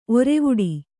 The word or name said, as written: ಒರೆವುಡಿ